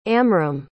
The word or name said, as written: amram